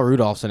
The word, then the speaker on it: Rudolph: I'm gonna ask you why you continue to pronounce the L in his name. – Because his name is Rudolph. – His name is Rudolph. – His name is Rudolph. – The L is silent.